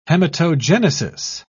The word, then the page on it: hematogenesis: British English